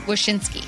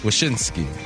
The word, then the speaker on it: Wyshynski: We've spoken to Andy Ide, and we just talked to Greg Wyshynski. Did I say it right? – Wyshynski. – Wyshynski.